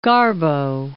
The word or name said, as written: Garbo